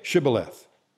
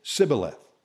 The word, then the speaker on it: Shibboleth: When he said, no, they would say to him, then say Shibboleth. And he would say Sibboleth. He could not pronounce it right.